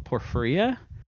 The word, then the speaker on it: porphyria: but it's called porphyria. It's P-O-R-P-H-Y-R-I-A.